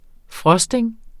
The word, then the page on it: frosting: ˈfʁʌsdeŋ; ˈfɹʌsdeŋ